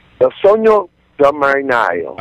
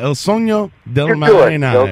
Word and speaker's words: Il Sogno del Marinaio: Il Sango del Marinaio? – Il Sogno del Marinaio. – Ah, Il Sogno del Marinaio. – Il Sogno. Yeah, repeat after me.